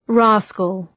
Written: rascal

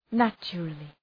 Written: naturally

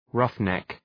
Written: roughneck

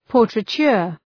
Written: portraiture

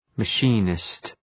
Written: machinist

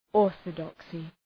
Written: orthodoxy

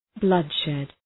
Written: bloodshed

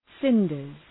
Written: cinders

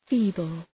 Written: feeble

